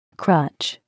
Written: crutch

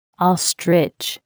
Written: ostrich

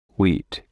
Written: wheat

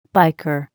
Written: biker

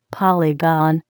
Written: polygon

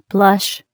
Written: blush

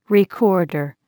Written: recorder

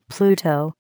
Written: Pluto

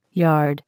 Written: yard

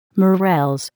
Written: morels

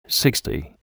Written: Sixty

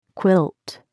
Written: quilt

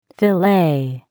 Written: filet

